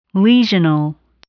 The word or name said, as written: lesional